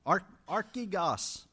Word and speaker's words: archegos: It's can probably pronounce it better than I can, but A-R-C-H-E-G-O-S-R-R. Pronounce it for me. Archigos.